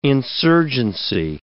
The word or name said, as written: insurgency